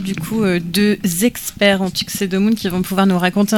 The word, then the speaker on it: Tuxedomoon: Et on vient d'entendre, alors j'ai toujours du mal à prononcer le nom de ce groupe, Tuxedomoon.